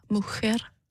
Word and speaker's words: mujer: Nej, fordi at, at j udtales, så det, det hedder mohair.